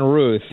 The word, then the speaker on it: Routh: Yeah, we're saying Ruth. – Ruth.